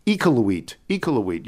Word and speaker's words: Iqaluit: Sarah Beth from Toronto, but currently, and it's pronounced Iqaluit. You're probably saying it wrong, but it's okay.